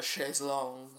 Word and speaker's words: chaise longue: Well, you gotta love a chaise longue. That's the French pronunciation.